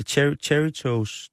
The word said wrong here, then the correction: Cheetos: Det er dem, som laver cheesy, det hedder Cheetos. – Cheetos, ja. Sådan udtaler man det.